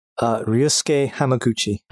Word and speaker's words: Ryusuke Hamaguchi: Ryusuke Hamaguchi.